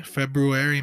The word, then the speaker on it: February: February? That's how you say it, right? February? – February, man. – There's a fucking R there that nobody ever pronounces.